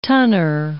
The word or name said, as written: tonner